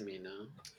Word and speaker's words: Cimino: How do you say his last name? – I would name? – Say Cimino. – Cimino.